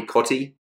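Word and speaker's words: Cottee: Our wisdom this week, mate, comes from former English footballer Tony Cotty. I think that's how you pronounce it, like Cotty's Cordial.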